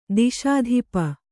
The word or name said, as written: ದಿಶಾಧಿಪ